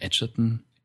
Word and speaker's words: Edgerton: oder Edgerton spricht man die aus, genau.